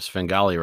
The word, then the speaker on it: Svengali: That's a first for the Microscope, a Svengali reference.